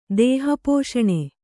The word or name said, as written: ದೇಹಪೋಷಣೆ